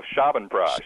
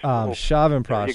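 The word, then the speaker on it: Chyawanprash: We've talked about Boswellia, Ashwagandha, Holy Basil, Turmeric, Shravanprash. Did I say that right? – Pretty close. Shravanprash. – Shravanprash. – There you go. Put the accent on the Shravanprash.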